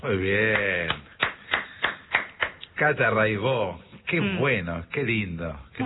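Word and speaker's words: Raybaud: Buenísimo, Cata Raibó. Se escribe Raibaus, métanse, búsquenla, Rai con Y, Baut con B larga. Catalina Raibaut, Raibó se dice, y bueno, cotidiano son 10 canciones, hay un documentalito muy lindo.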